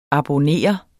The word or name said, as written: abonnere